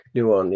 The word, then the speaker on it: Nouwen: It's Nouwen. Yeah. It's spelled Henry, but it's Henri Nouwen.